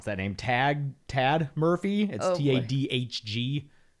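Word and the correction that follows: Tadhg: Pronounced. Okay. Tig. Rhyming with tiger without the R.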